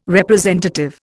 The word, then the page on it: representative: Indian